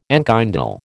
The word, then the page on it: enkindle: American English